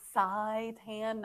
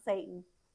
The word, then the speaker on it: seitan: And we are saying seitan, not Satan. I pronounce Satan differently, just so everybody knows. It's seitan.